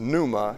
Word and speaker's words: pneuma: Well, the Greek word for spirit is, it's pneuma. It looks like pneuma, P-N-E-U-M-A, but the P is silent.